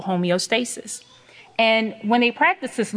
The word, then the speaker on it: homeostasis: Okay, so if you look at the pronunciation, homeostasis, homeostasis. Okay, remember that.